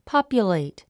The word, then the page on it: populate: pάpjulèit; ポピュレイト